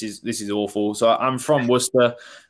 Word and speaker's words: Worcester: You're not far off. So it's Worcester. – Okay. – I mean, that one gets butchered all the time. It's Worcester, Worcester, Worcester, all sorts, but you weren't far off.